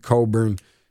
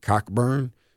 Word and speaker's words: Cockburn: Illinois has still been over an overachieving team as of this year, considering Kofi Coburn. I always want to call him Kofi Cockburn because it's spelled C-O-C-K-B-U-R-N, which is not a very attractive last name to have.